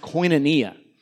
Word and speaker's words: koinonia: They used over and over again a term that in Greek is pronounced koinonia.